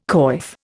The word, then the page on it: coif: Indian